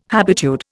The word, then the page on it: habitude: Indian